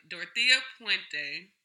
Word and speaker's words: Dorothea Puente: Dorothea Puente.